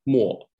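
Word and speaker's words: mo (墨): So how we write that character, pronounced as mo in Chinese for ink,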